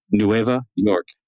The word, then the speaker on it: Nueva York: or in Espanol, Nueva York. Did you know that, John? Nueva York. That's how they say it.